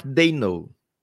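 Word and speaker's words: Daigneault: Ensina aí como é que fala o nome de Mark. Mark é fácil. E o resto? – É. Mark Dayno, ok?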